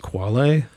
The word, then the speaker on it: quale: Yeah, I think that's pronounced quale. – Quale, okay. – Yeah, I believe. – Not quail. – Probably not quail.